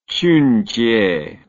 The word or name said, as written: Chunjie